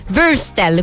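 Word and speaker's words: Würstel: Anche io nello scorso intervento ho detto Wüstel mille volte, si direbbe Wüstel, quindi oggi leviamo un po' di dubbi su come si scrive questa parolina in tutte le nostre liste della spesa.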